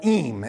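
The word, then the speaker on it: Eyam: And there was this small village just outside of London called Eme, is what I looked it up. It's, they said, how you pronounce it.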